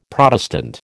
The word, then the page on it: Protestant: American English